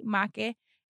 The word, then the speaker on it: Makhe: But Make is spelled M-A-K-H-E, Make.